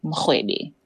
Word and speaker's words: Mrwebi: It's not written like it sounds. – So the surname is spelled Mrwebi. And my name is Siba Mrwebi.